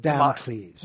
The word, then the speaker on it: Damocles: Sort of Damocles.